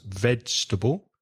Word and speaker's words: vegetable: Vegetable was correctly pronounced. That's a difficult word. It only has three syllables. Vegetable.